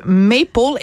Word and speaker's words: Maple: Alors, ça s'intitule Maple, et oui, il faut le prononcer à l'anglaise, euh, de David Goudreau, c'est publié chez Stanquet.